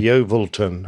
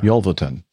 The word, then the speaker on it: Yeovilton: Yeovilton. – Yeovilton on Wednesday afternoon.